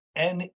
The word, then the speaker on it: Aisne: And the place we're going to talk about today is A I S N E. It's pronounced N, and it's in northern France.